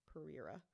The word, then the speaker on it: Pereira: And this was where 5-year-old Elise Pereira, I believe is how you say her name, P-E-R-E-I-R-A. Pereira. – Wait, say it again. – P-E-R-E-I-R-A.